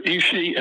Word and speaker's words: Ishii: Now, you mentioned Ishii, and that's spelled I-S-H-I-I. Ishii,